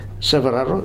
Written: Severaro

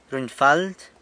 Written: runyfald

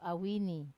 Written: awini

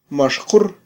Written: maxqur

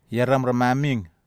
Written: ieram ramamig

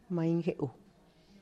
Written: maingeu

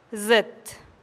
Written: zit

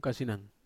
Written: kasinan

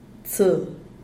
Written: tse

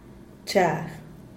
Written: chag

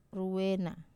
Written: ruwera

